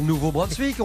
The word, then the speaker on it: Nouveau-Brunswick: C'est bien comme ça qu'on prononce, hein, Nouveau-Brunswick.